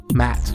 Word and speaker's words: Matt: So, my name is Matt, and in case you didn't know that, that rhymed with Matt.